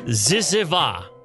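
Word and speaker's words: zyzzyva: Yeah, ziziva. That's how it's pronounced. Ziziva.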